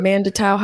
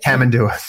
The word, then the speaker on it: Tamandua: But Mandatau, how do you say it? – Tamandua.